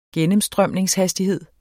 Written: gennemstrømningshastighed

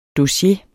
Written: dossier

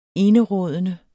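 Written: ENERÅDENDE

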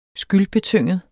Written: skyldbetynget

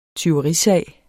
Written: TYVERISAG

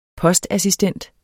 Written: postassistent

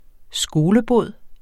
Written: skolebod

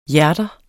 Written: hjerter

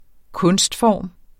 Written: kunstform

